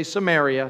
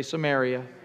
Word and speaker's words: Samaria: Okay, everybody say Samaria. Say Samaria. Okay, now when I say Samaria, you say it like that. You say it sounds Samaria. Samaria. It sounds so nice, like Mary. Maria. I'm going to marry you. That's what it sounds like.